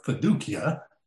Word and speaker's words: fiducia: It's fiducia, or fiducia, as I've heard it pronounced, and it refers to personal trust and reliance.